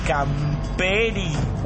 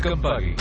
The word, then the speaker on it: Campari: ¿Me das un Camperi? – ¿Un qué? – Un Campala. – ¿Perdón? – Campari. El Pásese de casa. ¿Seguridad? – ¡No! – Ricardo Barilari, el hombre que no sabe decir Campari.